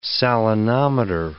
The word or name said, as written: salinometer